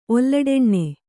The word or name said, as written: ಒಲ್ಲಡೆಣ್ಣೆ